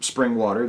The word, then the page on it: springwater: American English